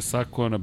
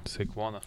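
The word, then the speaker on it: Saquon: Uh, gde vidite Sakona Barklija? Sekvona.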